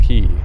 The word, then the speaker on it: Quay: It's spelled Q-U-A-Y. Quay, you would assume. But I learned in Vancouver that Lonsdale is called Lonsdale Key.